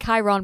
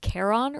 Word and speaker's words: Charon: Again, it's like, it's spelled like Chiron, but it's Charon or something.